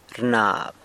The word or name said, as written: rnab